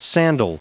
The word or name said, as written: sandal